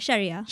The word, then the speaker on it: Sharia: Yeah, Sharia.